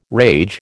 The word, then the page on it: rage: American English